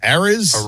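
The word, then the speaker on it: Arraez: Arias. – Yeah. I mean, Jesus Christ. But Luis, how do you say it again? – Arias.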